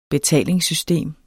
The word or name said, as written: betalingssystem